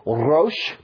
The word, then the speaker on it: Rosh: If you hear the middle syllable, Rosh, Berosh, Bereshith. Say that with me. Bereshith. Very good. The middle word is Rosh. R-O-S-H.